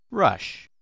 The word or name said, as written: rush